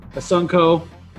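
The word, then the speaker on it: Assunção: Number 8, we got Rafael Asunso. That's how you say it.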